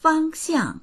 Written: fang1xiang4

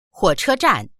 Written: huo3che1zhan4

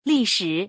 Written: li4shi3

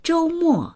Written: zhou1mo4

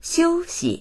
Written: xiu1xi

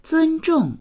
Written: zun1zhong4

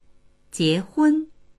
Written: jie2hun1

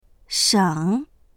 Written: sheng3

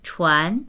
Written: chuan2

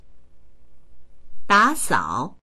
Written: da3sao3

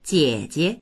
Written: jie3jie

